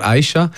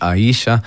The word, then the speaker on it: Aisha: Weil die Leute sagen immer Aisha. – Ja. – Und das ist so, ich habe einen Hund gekannt, der so geheißen hat. Und das ist wirklich Betonung auf dem I, so Spanisch Aisha.